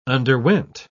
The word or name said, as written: underwent